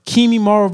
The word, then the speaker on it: Kimimaro: And then the one fight that I love so much in Naruto was when I think it was Kimimaru. I think I said that right. Kimimaro versus Rock Lee.